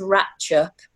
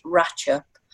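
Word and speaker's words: Rattlinghope: But in a village nearby that's called Ratchup, it's spelt Rattling Hope, but lots of local people pronounce it Ratchup.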